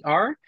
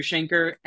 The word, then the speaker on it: Shainker: That's Shainker. Kind of rhymes with the word banker, Andrew Shainker.